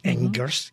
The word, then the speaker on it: Angers: Well, in that case, I have to invite you to go to Angers. I spell it in English, Angers, A-N-G-E-R-S, west of Paris.